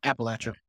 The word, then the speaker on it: Appalachia: Yeah, Appalachia.